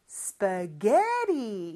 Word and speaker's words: spaghetti: 그리고 마지막 요 T, 물론 T가 두 개지만 영어는 보통 자음이 두 개 있으면 하나만 발음해주거든요. 그래서 하나 있다라고 가정을 했을 때 T 발음은 모음 소리 사이에 껴서 이것도 리을처럼 발음이 돼요. 아주 짧게 해줘야 돼요. 대신 spaghetti, spaghetti 이렇게 발음합니다.